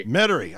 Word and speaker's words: Metairie: Metairie.